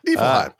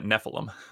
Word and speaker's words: Nephilim: I always want to say Niflheim because I played Final Fantasy VII when I was so young, and I'm always like, Niflheim. Nephilim. – Nephilim. Yeah. For those curious how to pronounce that, Nephilim.